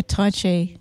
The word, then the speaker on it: Tai Chi: I don't know how do you say that? Tai Chi, is it? – Yeah, Tai Chi, yeah.